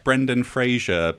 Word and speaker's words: Brendan Fraser: Anyone said his name correctly yet? – Frazier. Brendan Frazier.